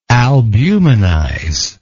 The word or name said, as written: albuminize